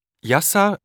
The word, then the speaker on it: Yasar: Jutta schreibt man J-U-T-T-A und Albrecht, meinen Nachnamen, A-L-B-R-E-C-H-T. Drei. – Und wie heißt du? – Yasar Öztürk. – Kannst du das buchstabieren? – Ja. Yasar schreibt man Y-A-S-A-R und Öztürk Ö, also O-Umlaut, Z-T, U-Umlaut, R und K.